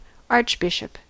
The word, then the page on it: archbishop: American English